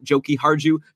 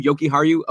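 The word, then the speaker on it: Jokiharju: It's not Joki Harju, not Yoki Harju, Yoki Haru.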